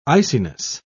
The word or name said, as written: iciness